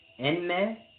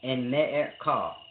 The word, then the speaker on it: Enmerkar: Now, when you're dealing with, it's a deep thing when you're dealing with Enmerkar, because you pronounce his name in the Sumerian spelling, Enmerkar, Enmerkar, America.